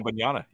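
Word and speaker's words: Wembanyama: Yeah, and then this Wembyama. I know I'm not saying that right, but no, you got it. – Wembyama.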